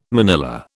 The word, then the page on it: manilla: British English